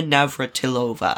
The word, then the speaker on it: Navratilova: Her name is A, Tracy Austin, B, Billie Jean King, C, Martina Navratilova. I butchered that. – No, that was very good.